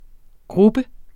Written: grubber

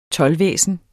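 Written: TOLDVÆSNET

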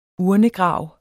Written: urnegrav

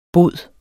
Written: bod

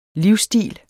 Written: livsstil

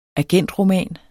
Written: agentroman

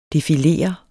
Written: DEFILERER

